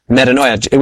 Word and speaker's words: metanoia: metanoia, which sounds like the word paranoia, mm-hmm.